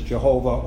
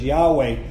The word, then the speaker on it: Yahweh: That represents the tetragrammaton, the four sacred letters transliterated as YHWH, and we pronounce it as Jehovah or as Yahweh.